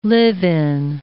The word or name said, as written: live-in